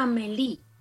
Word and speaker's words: Amélie: Se escribe Amélie, pero se pronuncia Amélie.